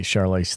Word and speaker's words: Charlize: Well, that's our review of The Old Guard, the Charlize Theron movie.